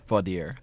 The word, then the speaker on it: voir dire: Is it voir dire? Is that how you pronounce it? Voir dire. Thank you.